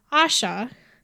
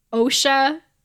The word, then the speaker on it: Asha: Now, remember when I told you to really over-pronunciate Osha? This was why. Because there's an Asha. Because, like, if you say Asha, you're just like, wait, are you saying Osha or Asha?